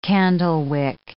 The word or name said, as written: candlewick